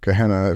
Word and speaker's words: Gehenna: So when you talk about Jesus referring to Gehenna, if I've pronounced that correctly, yep.